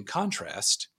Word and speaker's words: contrast: In contrast, how do you say it, since you're from Canada? I feel like you always say contrast differently. – Contrast? – Yeah, there it is. Thank you. Subtle.